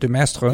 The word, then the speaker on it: de Maistre: De Maistre? – Yeah, de Maistre.